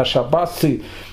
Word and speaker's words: шабас: Интересно, они же все были ашкеназы, ашкеназы букву «тав» в конце, они произносят не как «т», как в современном Израиле, а как «с». Поэтому не шабаты, а шабасы. Э, поэтому слово дат, отсюда, кстати, будет происходить слово дати.